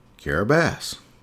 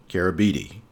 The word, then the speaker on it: Kiribati: For instance, the letters TI actually make this S sound. So the name of the country in Gilbertese is Kiribati, even though it looks like Kiribati.